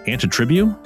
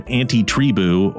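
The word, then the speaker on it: Antitribu: I had to think for a minute because I think it was, we always pronounce it Anti-Tribu. – Okay. Yeah, that also sounds right. – But it's A-N-T-I-T-R-I-B-U. So Anti-Tribu or Anti-Tribu, I think is just how you pronounce it.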